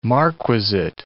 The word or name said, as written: marquisate